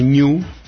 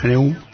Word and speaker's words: GNU: Stallman sugiere que se pronuncie GNU, aunque en castellano suena mejor GNU. Que se puede observar que el logo pues se trata de este animal, de un ñu. Para evitar, bueno, en inglés hay problemas porque por lo visto la pronunciación se parece mucho a new, a nuevo en inglés.